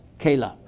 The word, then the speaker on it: Keilah: Keilah.